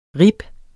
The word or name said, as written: ribs